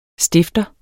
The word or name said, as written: stifter